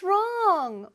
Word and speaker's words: wrong: What's wrong? 뭐가 문제야? 왜 그래? 뭐 문제 있어? What's wrong? 발음 잘 기억하고 있죠? Wrong. 어디에도 닿으면 안 돼요, 혀가.